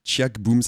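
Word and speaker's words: Ciac Boum: Le morceau que vous venez d'écouter s'appelle « Les filles de Noirmoutier » de Tchiak Boum, ça s'écrit C-I-A-C et Boum comme Boum, B-O-U-M.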